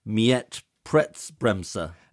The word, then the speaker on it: Mietpreisbremse: So I think you mentioned what's happening in other European countries, so in Germany they have, and I'm going to get the pronunciation wrong, in Berlin I think Mietpreisbremse. – That sounded perfect.